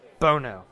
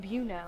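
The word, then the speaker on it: Buono: Angelo Bono is how you pronounce it. – Bono. – I know it's spelled weird and people are like, what, but everything. – I was saying Buno. – Every documentary I watch would just say Bono. It has a U in it.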